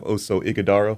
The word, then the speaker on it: Oso Ighodaro: Yeah, well, first of all, good morning, and it's great to have Oso Ighodaro.